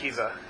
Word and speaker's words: Akiva: Akiva, A-K-I-V-A.